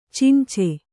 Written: ಚುಂಚು

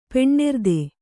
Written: ಪೆಣ್ಣೆರ್ದೆ

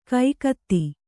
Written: ಕಾಗದ

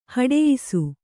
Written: ಹೊಡಯ್ಸು

